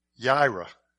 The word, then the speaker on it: Yireh: Yireh. And I say that because that's really how you say it, because it messes up the song Jehovah Jireh when you try to sing that.